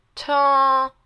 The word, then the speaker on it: tone: 大家都会念成痛，中文的那个很痛的痛，不，tone，它要有鼻音哦。先是从鼻子上面 tone on，然后变成嘴巴的音，然后嘴巴变成一个 O 型 tone，然后最后是一个 n 的音结尾 tone，OK？